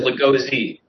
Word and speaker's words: Lagoze: It's Lagoze.